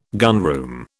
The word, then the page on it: gunroom: British English